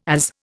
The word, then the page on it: AZ: Indian